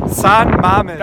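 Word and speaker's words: San Mamés: San Mames. San Mames. Das ist genau richtig ausgesprochen.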